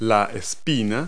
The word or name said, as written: spine